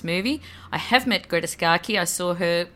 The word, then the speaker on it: Scacchi: Scacchi, yep.